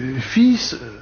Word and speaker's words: fils: Mais la prononciation ancienne, c'était fi.